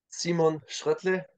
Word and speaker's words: Simon Schröttle: Simon Schröttle is the German pronunciation. – A little bit different to Stu's attempt earlier then.